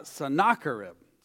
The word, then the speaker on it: Sennacherib: Somebody told me it was Sennacherib, but that sounds good. Sounds tasty, actually. But the way it was described in this reading, it's Sennacherib.